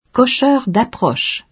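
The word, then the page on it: cocheur d’approche: Quebec French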